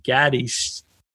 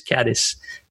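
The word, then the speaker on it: Cádiz: I'll use the Spanish pronunciation, Gadis or caddy, caddy. I got, I got a, a caddy. That's how they. I don't. I can't say it like they say it, but anyway, in my terms, it's caddis, caddis, caddis. That's how I say it.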